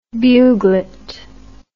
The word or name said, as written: buglet